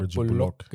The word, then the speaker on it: Bullock: Aber Fakt ist, man muss auch seine Teammates ein wenig in mit reinnehmen, weil es kann nicht sein, dass ein Bullock, habe ich ihn richtig ausgesprochen? – Ja, Bullock. – Genau, so will er ausgesprochen werden.